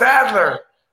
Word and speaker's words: Adler: You know, it was like A-L-L-D-E-R. So I said, Stephen Alder. And they said, no, it's Adler.